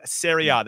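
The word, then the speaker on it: Serie A: SERIA. That's S E R I E A because they pronounce their A as ah.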